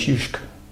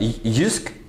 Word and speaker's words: Jysk: I think the biggest one is Jysk. – Jysk. – Yeah, oh, you call it Jysk? You call it Jysk? – Jysk is the Danish pronunciation, and Jysk is a part of Denmark.